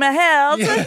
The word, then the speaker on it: Held: Hoe zouden zij zijn naam uitspreken? Team Held. Ja. – ik denk precies zo.